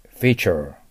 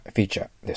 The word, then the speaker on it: feature: これをフィーチャーと言ったらダメです。あーという発音しないと。うがいをする感じで、はい、 feature。 feature ですね。この機種は自動停止機能がフィーチャーです。